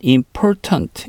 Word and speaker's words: important: It's not important. 이렇게 얘기했습니다. 마찬가지로 important. T가 중간에 있을 때 발음 important. 거의 이렇게 발음이 되네요. Important. 이렇게 발음이 됐어요.